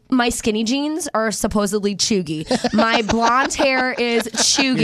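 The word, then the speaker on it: cheugy: Is it choogy? – It's choogy. You knew it was choogy before I even said it.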